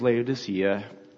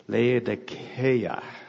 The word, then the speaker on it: Laodicea: So Laodicea, what we say as Laodicea, would be for the Greeks pronounced Laodikeia. So it's what we say is the C, the S sounding C in Greek would be the kappa, which would have it Laodikeia.